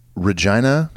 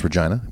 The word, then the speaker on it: Regina: I always feel uncomfortable saying Regina. I know it looks like Regina, like the woman's name. But there are cities around the country named Regina.